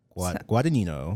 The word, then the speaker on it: Guadagnino: Luca Guadagnino. Guadagnino.